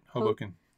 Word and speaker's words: Hoboken: Been a fan of the podcast, avid vlog watcher, and even had the pleasure of meeting Andrew in Hoboken. How do you say that? – That's right, Hoboken.